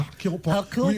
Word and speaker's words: Hercule Poirot: Hercule Poirot. You say it with French accent. – Yeah, Hercule Poirot. You have to say it with a phlegm.